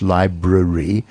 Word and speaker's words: library: And sometimes it's there because it's hard to pronounce, like the R sound in English, library, often library, right? And February rather than February.